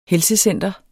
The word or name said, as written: helsecenter